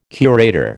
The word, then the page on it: curator: American English